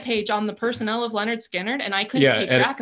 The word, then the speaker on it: Lynyrd Skynyrd: The album is helpfully entitled, pronounced Lynyrd Skynyrd. So that people would know how to pronounce it.